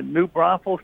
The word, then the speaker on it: New Braunfels: It's New Braunfels, Texas. – Okay, New Braunfels, Texas.